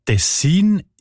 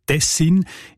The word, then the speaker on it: Tessin: Die Variante mit der Betonung auf den ersten Silben und mit kurzem I, also Tessin, ist seltener.